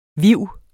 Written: viv

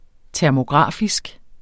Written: termografisk

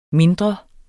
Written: mindre

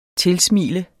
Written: tilsmile

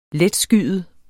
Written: letskyet

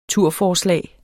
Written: turforslag

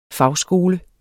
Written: fagskole

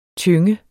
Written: tynge

